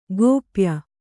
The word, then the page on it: ಗೋಪ್ಯ: gōpya; kannada